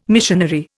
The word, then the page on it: missionary: Indian